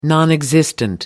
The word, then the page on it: nonexistent: British English